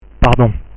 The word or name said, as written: pardon